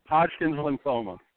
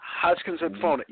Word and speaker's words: Hodgkin's lymphoma: How do you say it? – Hodgkin's lymphoma. – Hodgkin's lymphoma. Yeah, he's just the kid.